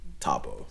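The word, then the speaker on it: Thabo: If you put the H, then if you're white, you say my name perfectly. But without the H, it's a different story. So anyway. Yeah, soft B, hard B, Tabo and Tabo.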